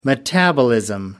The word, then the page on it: metabolism: American English